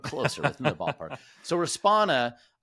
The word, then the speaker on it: Respona: And I could not pronounce the company right. I was Respirio, Responia, and all these different things, but it was Respona, right? Am I saying it right? Or am I probably saying Respona.